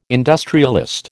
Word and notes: industrialist: American English

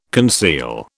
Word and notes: conceal: British English

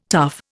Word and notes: tuff: Indian

